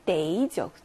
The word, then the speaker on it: 得: 자, 뭐뭐 해야 해라고 할때 발음 데이죠.